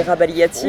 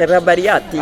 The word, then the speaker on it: Rabagliati: Pour les Français, c'est Rabagliati. Oh, hein. Okay. Pour les Italiens, c'est Rabagliati. Okay.